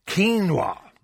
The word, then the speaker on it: quinoa: And do you pronounce it quinoa, do you? – Quinoa. – Quinoa, apparently. – That's it.